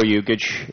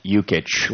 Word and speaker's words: Yukich: My name is Joe Yukich, and you pronounce that Yukich like you catch a football.